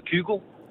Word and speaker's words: Kygo: Jeg siger Kygo.